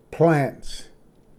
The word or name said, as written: ᏚᏰᎬ